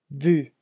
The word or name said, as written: vu